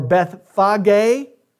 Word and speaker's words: Bethphage: As Jesus draws closer to Jerusalem, he comes upon 2 villages, Bethpage or Bethphage or Bethphage is how it's pronounced in the Greek, and Bethany.